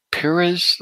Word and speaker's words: Piriz: I hope I'm pronouncing that right. Piriz, looks like P-I-R-I-Z. – Yes.